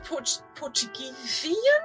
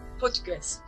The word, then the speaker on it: Portuguese: And you're a very proud Portuguesian? Portuguese? How do you say that? – Portuguese. – Portuguese.